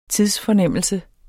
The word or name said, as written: TIDSFORNEMMELSE